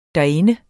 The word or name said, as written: derinde